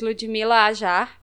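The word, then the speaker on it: Ludhmila Hajjar: So the person who was being supposedly considered for health minister was Ludmila Aja. – That's correct, yes. That's exactly how you pronounce that name.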